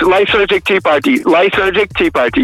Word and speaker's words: Lysergic Tea Party: Then 2013, Lysergic Tea Party. Did I pronounce it correctly? – Correct, Lysergic Tea Party, yes, that's correct, yeah.